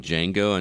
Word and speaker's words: Django: Well, you don't have a problem with Django Unchained, but Mordecai gives you a problem? – Yeah. Yeah. – The D. – The D is silent.